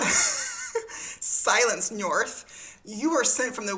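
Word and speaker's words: Njörðr: Okay, so then Njörth, did I say that right? – Njörth, yep, you did.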